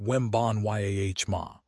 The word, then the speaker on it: Wembanyama: Victor Wembanyama, pronounced Wembanyama, recently signed an extension taking 25% of the cap to maintain team flexibility.